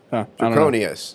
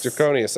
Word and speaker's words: Draconequus: Draconequus? I can't remember how she pronounced it. – Draconius. – Draconius. – Draconius. That's right.